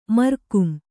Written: ಮರ್ಕುಂ

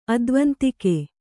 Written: ಅದ್ವಂತಿಕೆ

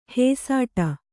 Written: ಹಸಿಟ್ಟು

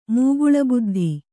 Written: ಮೂಗುಳಬುದ್ಧಿ

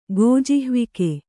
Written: ಗೋಜಿಹ್ವಿಕೆ

